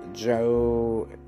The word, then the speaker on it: Joannides: Again, the other book is The Guide to Getting It On by Paul Joannides. Oh, I can't pronounce that name. It's Joan and then N-I-D-E-S.